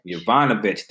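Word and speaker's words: Yovanovitch: Uh, I'm going to pronounce her name Jovanon, Jovanovic. Jovanovic. – It sounds like Jovanovic. – Jovanovic. There we go.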